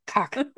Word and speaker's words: Koch: This H is silent. Koch. Koch or Koch.